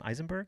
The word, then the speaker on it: Eisenberg: Eisenberg? – Yes.